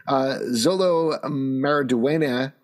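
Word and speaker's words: Xolo Maridueña: However, Zolo Maraduena, that's how you pronounce his name.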